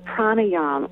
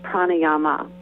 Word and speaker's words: pranayama: It's called pranayama, or sometimes it's pronounced pranayama.